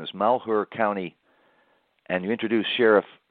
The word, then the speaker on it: Malheur: Well, it sounds like he isn't so sure at first, but and you're right, you pronounce it right. It's Malheur County, and he's the sheriff in that county.